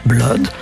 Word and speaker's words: blood: Bref, ces 4 exceptions se prononcent soit comme cut ou mud, ce sont le sang, blood, et l'inondation, flood.